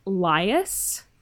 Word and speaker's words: Lihos: It's apparently Lias. – Mm-hmm. – It's Greek. – Oh. – L-I-H-O-S, but it's pronounced Lias.